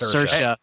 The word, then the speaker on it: Saoirse: Cersei Ronan. Did I say that right? I always feel like I'm going to butcher that one. – I think it's Saoirse.